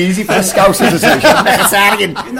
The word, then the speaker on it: Mkhitaryan: One player who I'd love to have had the um, Michael Wacky Wacky Wacky whatever his name was. Mkhitaryan. Mkhitaryan.